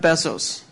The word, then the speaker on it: Bezos: Imagine being Jeff Bezos.